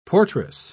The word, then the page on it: portress: British English